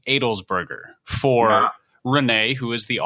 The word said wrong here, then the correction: Adelsberger: It's actually pronounced closer to Adelsberger, as in like the singer Adele is eating a hamburger. So Adelsberger.